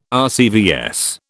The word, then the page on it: RCVS: British English